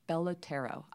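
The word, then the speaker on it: Belotero: So unlike, I don't know if I mentioned this, most of the fillers like Juvederm and Restylane and Bellotera, I believe are 6 months to a year, just so people know that if they didn't realize There's one called Bellotera. – Bellotero. I'm saying it wrong, yes.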